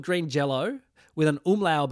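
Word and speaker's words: Green Jellÿ: But they say, the band, the umlaut over the Y makes it an O sound. So they're still pronounced Green Jello.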